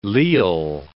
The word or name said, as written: leal